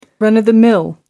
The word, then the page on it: run of the mill: American English